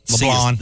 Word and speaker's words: LeBlanc: And that's why Derek LeBlanc, did I say that right? – Yep. – LeBlanc. The C is silent.